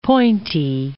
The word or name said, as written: pointy